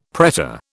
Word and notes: pretor: British English

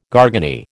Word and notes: garganey: American English

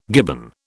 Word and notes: gibbon: British English

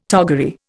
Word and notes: toggery: Indian

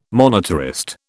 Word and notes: monetarist: British English

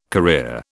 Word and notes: career: British English

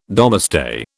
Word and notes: Domesday: British English

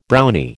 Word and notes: Brownie: American English

